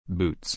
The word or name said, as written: boots